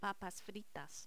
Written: papas fritas

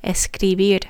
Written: escribir

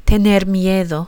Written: tener miedo

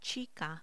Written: chica

